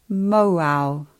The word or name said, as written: Mauao